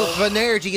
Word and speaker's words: venerdì: Venerdì is how you say it, to be honest with you.